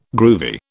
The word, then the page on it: groovy: British English